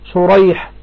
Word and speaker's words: شريح: ها، النطق في سريج وشريح، تمام، سريج وشريح مختلف، النطق مختلف. النعمان والنعمان واحد، يبقى الاختلاف في أسماء الأبناء والاتفاق في أسماء الآباء. قال: وكلاهما مصغر، شريح سريج، فالأول بالمهملة يعني بالسين والجيم، سريج ابن النعمان ابن مروان اللؤلؤي البغدادي روى عنه البخاري وروى له أصحاب السنن، والثاني بالمعجمة الشين شريح والحاء المهملة شريح.